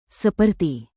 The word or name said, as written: seperti